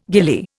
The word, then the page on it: ghillie: Indian